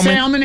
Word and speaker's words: salmon: Salmon has that, well, that L is supposed to be silent, ain't it? – Kind of, sort of.